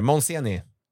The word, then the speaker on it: Monseni: Nu har du hunnit byta till, hur du uttalas det, Monseni. – Ja, stämmer. Monseni.